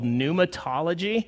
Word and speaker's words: pneumatology: And if you're curious about it, because I had somebody at the first service that was, it's pneuma with a P-N-E-U. He spelled it phonetically, and it took him to pneumatology, which was the study of nematodes and roundworms.